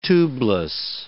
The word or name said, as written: tubeless